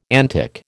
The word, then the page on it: antic: American English